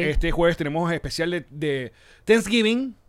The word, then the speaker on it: Thanksgiving: Thanksgiving. Que eso no era una serie, Sense8. – Es que tratas como de pronunciar la TH con Thanksgiving.